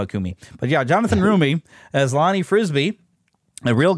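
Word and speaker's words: Roumie: Rumi. – Kumi. Did I say it right? – I thought it was Rumi. – Let me look him up again here. It's on my page. Yeah, it is Rumi.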